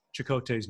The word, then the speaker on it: Chakotay: The emphasis is on the CHA. She says Chakotay. And then, as well as, yeah, we say Chakotay, and she goes Chakotay.